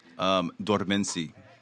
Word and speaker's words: Dormence: Dormence.